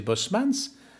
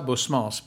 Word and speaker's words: Bosmans: que j'ai prononcé Bosmans. Et Bosmans, c'est peut-être le Bosmans de la comédie bruxelloise. Et qu'à Paris, tout le monde prononcera Bosmans, bien entendu.